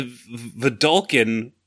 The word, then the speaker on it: Vedalken: Deputy of Detention is a Vedalken wizard, which I also pronounce Veldekin. – Oof. – Oh. Yeah, that one's just wrong. – I know. I transpose the letters.